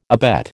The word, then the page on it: abet: American English